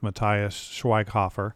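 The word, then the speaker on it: Matthias Schweighöfer: I'm not real familiar with Matthias Schweighofer, but as Dieter, I thought he was decent.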